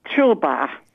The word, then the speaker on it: Szczyrba: Und das heißt Tschirba. – Tschirba, ja. – S-Z-C-Z-Y-R-B-A.